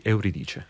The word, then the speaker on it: Euridice: Giotti al canto mio from Peri Euridice.